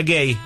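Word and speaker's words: Guéhi: Consa, y se escribe Gehi, pero se pronuncia Gay, el central, junto con Nico O'Reilly o Spence.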